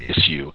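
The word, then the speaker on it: Issuu: I pronounce it issue, ISSU, or you can read it on your iPad.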